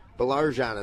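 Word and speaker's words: Baillargeon: Going to give people an appreciation of the pronouncing challenge. It's B-A-I-L-L-A-R-G-E-O-N. Belarjan is what we've settled on here.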